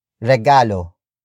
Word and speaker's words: regalo: Regalo. Regalo. Regalo is spelled as R-E-G-A-L-O.